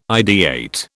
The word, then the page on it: ideate: British English